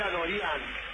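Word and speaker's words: Adoryan: Yo dije Adorián y me parece que se pronuncia así. – ¿Y por qué es de ascendencia armenia? – Bueno, Adorián entonces será.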